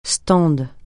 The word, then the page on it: stand: francais